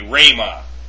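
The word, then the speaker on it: rhema (ῥῆμα): When used with rho, however, the rough breathing is generally not pronounced. So that would be rhema.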